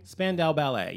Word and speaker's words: Spandau Ballet: I think it's called, is it Spandau Ballet? – Spandau Ballet, yes.